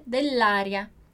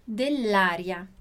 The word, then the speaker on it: dell'aria: E aí, por isso que a gente vai dizer dell'aria. Então, eu juntei o som de dois L's, então nossa pronúncia ali da doppia consonante, junto com o A. Então, dell'aria.